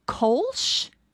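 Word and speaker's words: Kölsch: The Dandelion Honey Kolsch. Is that how you say it? K-O-L-S-C-H.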